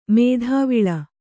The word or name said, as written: ಮಾದವಾಳ